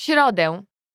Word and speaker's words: środę: W środę.